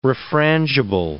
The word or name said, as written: refrangible